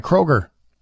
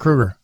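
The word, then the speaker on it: Kroeger: Most Canadians know him as Chad Kroger, like the grocery chain, not Chad Kruger. So that's wrong. Speaking on Loudwire, Lisa broadcast, Kruger explained that his surname is often butchered. However, he's from Hannah, and he insists it should be pronounced the same as Freddy Kruger, you know, the nightmare guy.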